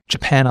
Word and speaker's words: Japan: Try saying the word Japan aloud. Ja, pan. Notice how the pan part of the word stands out? That's because this syllable is stressed.